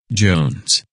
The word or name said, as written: Jones